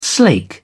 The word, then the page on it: slake: British English